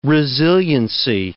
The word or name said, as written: resiliency